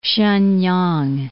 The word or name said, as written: Shenyang